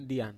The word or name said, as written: dian